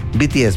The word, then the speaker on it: BTS: BTS, perdón. Iba a decir pies, pero es BTS. Lo dije bien.